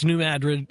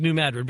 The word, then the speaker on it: New Madrid: I've heard a lot of different pronunciations of that, especially from guys down in that area. But well, I lived there. It was New Madrid.